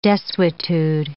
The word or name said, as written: desuetude